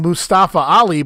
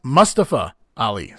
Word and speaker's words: Mustafa Ali: Well, I call him Mustafa Ali, but he claims that it's pronounced Mustafa Ali.